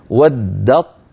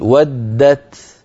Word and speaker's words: ودت: فيصير النطق بطاء مشددة لا يبقى للتاء أثر، هكذا ودت طاء ودت طائفة، هذا أيها الإخوة في حالة الوصل. فإن وقفنا نعود يعود الأمر إلى الأصل فنقول ودت ودت بتاء ساكنة،